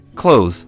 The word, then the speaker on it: clothes: Right, close. It sounds like the opposite of open.